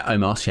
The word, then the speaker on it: Omos: Yeah, Omos, yeah.